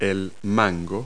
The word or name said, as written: hand tool